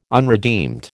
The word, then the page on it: unredeemed: American English